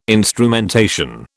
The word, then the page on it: instrumentation: British English